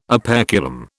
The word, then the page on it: operculum: British English